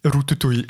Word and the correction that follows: Ratatouille: Ratatouille ist und äh,